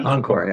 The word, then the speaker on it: Encore: How do you pronounce it? E N C O R. Yeah, Encore.